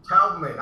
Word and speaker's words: Taubman: Taubman.